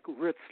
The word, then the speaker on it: Ritz: It's pronounced like Ritz, like the cracker. – Oh, Ritz. – Edward Ritz. – Yeah. Okay. – R-I-T-Z.